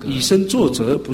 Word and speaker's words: 以身作则: 这样中文跟我走来了解啊，闽南语以身作则，不是以身作贼啊！有的发音不准就这样嘛。